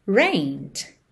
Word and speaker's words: rained: It rained. It rained, ok? Então existe o verbo to rain. No passado, rained, escreve rained, mas o E não é pronunciado, tá? Então você vai direto do N pro D.